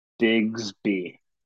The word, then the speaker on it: Bigsby: How do you pronounce his last name? – Bigsby. – Bigsby. Okay, that's right.